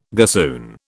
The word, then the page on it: gossoon: British English